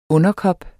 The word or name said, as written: underkop